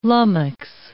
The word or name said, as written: lummox